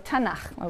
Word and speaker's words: Tanakh: Tanakh, which is really the letters T, N, and Ch. And they put little A's in there to make it easy to pronounce, because T is hard to pronounce. So Tanakh, okay?